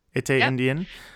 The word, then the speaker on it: Etienne Indien: But congratulations to the winner, Etienne Indien, am I saying that right? – Yep. – Etienne Indien, wire to wire fashion. – Indien in French.